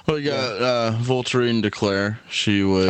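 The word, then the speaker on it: Voltairine de Cleyre: V-O-L-T-A-R-I. – Close. – I-N-E. V-O-L-T. Go ahead. – V-O-L-T-A-I-R-I-N-E. – D-E-C-L-E-Y-R-E. Yeah. – Voltairine DeClaire.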